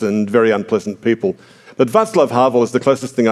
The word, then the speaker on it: Václav: People sometimes call him Vaclav because they see the C there as a C, but it, in Czech, the C without an accent on it is pronounced S, so it's like Václav, which is the Czech version of Wenceslas, actually.